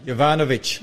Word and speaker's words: Yovanovitch: It's by Pamela Yanov. How do you say that? – Yovanovitch. Pamela, she was on our program not so long ago.